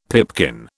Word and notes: pipkin: British English

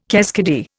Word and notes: keskidee: Indian

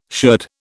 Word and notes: sherd: British English

